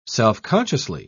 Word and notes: self-consciously: British English

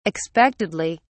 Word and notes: expectedly: British English

